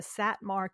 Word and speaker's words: Satmar: And so it's so it's the so apparently it's the um, so I'm look I looked it up so I could say it right. It's the Satmar community in Williamsburg,